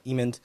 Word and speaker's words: Emond: So it's with Donald Gleason, Steve Carell, Linda Emond. I said her last name right this time. I said it wrong last time I interviewed her.